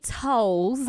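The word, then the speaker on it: Towles: So, my last name is Janae Tiles. – Oh, okay. – Not Towels. – Okay. My bad. – It's T-O-W-L-E-S.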